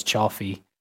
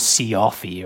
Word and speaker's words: Cioffi: So, the film, interesting that the old Shaft came up there, because the film stars Jane Fonda, Donald Sutherland, Charles, in Italian pronunciation would be Charles Cioffi, but I imagine he probably called himself like Charles Cioffi or something, because he was American, and the old Roy Scheider I mentioned there, but Charles Cioffi, I'm going to pronounce it like that because I live in Italy, but he was in Shaft.